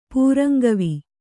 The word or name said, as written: ಪೂರಂಗವಿ